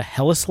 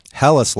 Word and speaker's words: Helles: Okay, so and now you guys made me self-conscious about pronouncing it, but this is a Helles Lager. – Helles Lager.